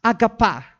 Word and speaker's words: agape: Ito na yung alam natin na sabi natin agape. Pero pronounce as agapa, no? In Greek, no?